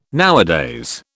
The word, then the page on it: nowadays: British English